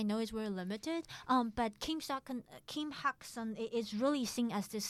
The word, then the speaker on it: Kim Hak-sun: Oh, so Kim Hak-sun. – Kim Hak-sun.